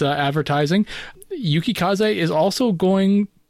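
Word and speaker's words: Yukikaze: Yakukaze. I can't say Yuki Kaze. – Yuki Kaze. It's four syllables. Yuki Kaze.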